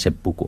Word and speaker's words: seppuku: Però llavors, com que és un compost, s'ha de llegir més amb una altra lectura xinesa, diguéssim, o més antiga, llavors els japonesos ho llegeixen seppuku. S'escriu igual, però hi ha com dues maneres de pronunciar-ho, o de... Sí. I llavors la més japonesa és seppuku.